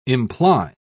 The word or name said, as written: imply